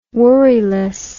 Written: worriless

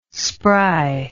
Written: spry

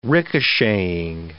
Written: ricocheting

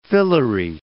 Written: phyllary

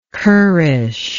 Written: currish

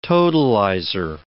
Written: totalizer

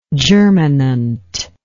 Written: germinancy

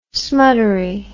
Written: smuttery

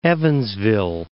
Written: Evansville

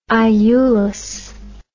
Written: Iulus

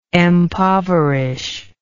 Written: empoverish

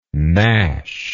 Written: gnashingly

